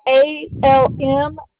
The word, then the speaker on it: Almand: Yeah, ALMAND. A-N-D.